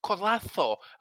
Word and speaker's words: Collazo: Is it Oscar Collazo? – Collazo. – Collazo. – Collazo. – Well, I can guarantee this way, like, going forward, my Scottish ass ain't going to be fucking Collazo.